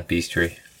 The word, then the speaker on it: bestiary: Yeah, bestiary.